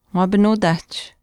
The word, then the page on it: waapinuutaahch: Chisasibi